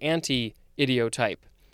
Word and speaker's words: anti-idiotype: Next and last word for this episode is anti-idiotype, or actually anti-idiotype. There is a hyphen. It is spelled A-N-T-I hyphen I-D-I-O-T-Y-P-E.